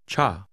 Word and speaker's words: 差: Cha. – Is pronounced with a falling tone.